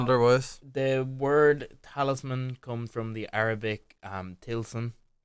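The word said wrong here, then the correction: tilsam (طلسم): or tilsam. My Arabic pronunciation is normally better. The word talisman comes from the Arabic tilsam,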